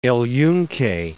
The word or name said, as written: Yunque, El